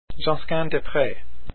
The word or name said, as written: Josquin